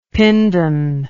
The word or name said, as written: pindan